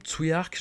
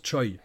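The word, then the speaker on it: Tsui: Voilà, par exemple, Tsuyark, je l'ai souvent prononcé pendant des années Tsuyark. Et puis, je me suis retrouvé face à Julien Carbon, qui a bossé avec lui, et qui prononce Choi. Et qui prononce tous les UI des noms et des prénoms hongkongais, Oi.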